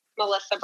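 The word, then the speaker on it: Mellisa: You can find me on Facebook, Melissa Brown.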